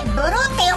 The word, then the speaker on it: 碧: はい、オープニングクイズの正解は 2番、紺碧の碧の字、碧って書いてブルーって読むよ。ブロックの感じでぬやが。